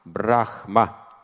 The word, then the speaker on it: Брахма: На четвертом уровне находится отношение Брамы. Брам, Брахма, так пишется, Брахма.